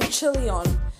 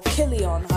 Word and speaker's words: Chilion: The name Chilion or Kilion, however you wish to pronounce it, that name means completion, destruction, failing.